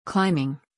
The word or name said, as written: Climbing